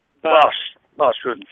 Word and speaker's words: Bas Rutten: Also have a follow-up about Bas Rutten. Is that how you say it? – Bas Rutten. – Bas Rutten? – Yes.